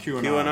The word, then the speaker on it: QAnon: And I don't know how to pronounce it. The QAnon. – QAnon.